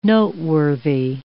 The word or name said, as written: noteworthy